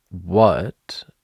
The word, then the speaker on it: what: What has an A, but guess what? Strong schwa. It's an But a strong one because it's important in the sentence.